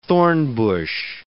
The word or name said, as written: thornbush